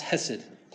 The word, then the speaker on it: hesed: In the Hebrew, it's pronounced hesed.